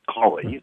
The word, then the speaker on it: Colley: By the way, Dana's last name is pronounced Colley, I believe.